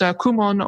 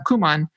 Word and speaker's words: Kumon: It's pronounced Kumon or Kuman.